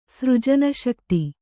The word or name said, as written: ಸೃಜನಶಕ್ತಿ